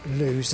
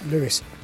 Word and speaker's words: Lewes: Lewes trip, which I incidentally was pronouncing wrong. It's not Lewes, as I said, it's Lewes.